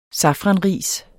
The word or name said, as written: safranris